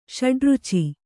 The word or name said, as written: ಷಡುರುಚಿ